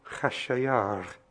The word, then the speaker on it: Khashayar: And I don't know if I dare to try and pronounce it in Persian, but it's something like Khashayar. Yes, I've got that right.